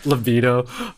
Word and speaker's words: libido: But yeah, for me, I think, maybe I think I got used to that, like, libido. I keep pronouncing it as libido. I know it's supposed to be libido, but the libido and Skins, like.